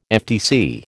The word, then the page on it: FTC: American English